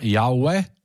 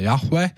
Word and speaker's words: Jahve: Un ebrejiem ir tāds ļoti svēts vārds, kā nu kurš to izrunā, Jauve, ja, vai Jahve.